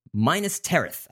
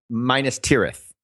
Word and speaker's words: Minas Tirith: They want to build a working city in the United Kingdom based on Minas Tirith. And I know I just pronounced that wrong, didn't I? – Minas Tirith. – Yeah, I knew the Tirith part was wrong.